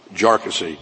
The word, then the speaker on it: Jarkesy: the way to pronounce the challenger's name here is not Jarkissi, as we idiots have been doing, but instead as Jarkissi, to which I can only respond, John Stewart calls him Jarkissi, I'm going to call him Jarkissi.